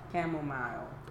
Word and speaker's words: chamomile: Camel what? – Chamomile. – Oh, chamomile. Sorry, that's chamomile tea.